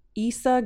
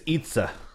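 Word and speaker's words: Eiza: Anyways, I might mispronounce her name. Issa Gonzalez? But here, I printed this for Issa. – You. – Issa. Issa Gonzalez. Is it a picture? – We think it's Itza.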